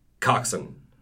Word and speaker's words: coxswain: It's pronounced coxswain or coxswain.